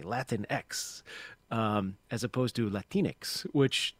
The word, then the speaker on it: Latinx: The sort of cutesy little helpful hint for folks that I've seen circulate a lot is it Latinx, it rhymes with Kleenex. So if you can say Kleenex, you can say Latinx.